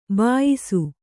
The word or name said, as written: ಬೇಯಿಸು